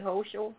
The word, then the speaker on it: Hochul: Governor Kathy Hochul, if I'm saying her name right, Hochul, H-O-C-H-U-L.